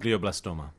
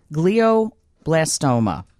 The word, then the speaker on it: glioblastoma: Glioblastoma. – Glioblastoma.